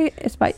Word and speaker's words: Ice Spice: ¿Se pronuncia Ice Spice? – Sí.